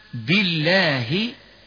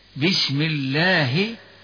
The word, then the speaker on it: الله: وهكذا ترقق اللام من اسم الجلالة كما ننطقها كذا، بالله، بسم الله، قل اللهم.